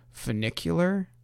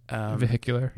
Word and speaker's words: funicular: It's a funicular. Funicular is how it's pronounced. – Vehicular? – No, funicular.